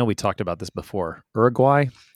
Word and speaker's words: Uruguay: People say it a lot different. Here we say Uruguay. – Right. – Uruguay.